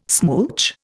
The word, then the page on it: smooch: Indian